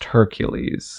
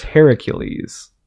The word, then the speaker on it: Heracles: In the Greek mythology, his name isn't pronounced Hercules. – Huh. – It's pronounced Heracles.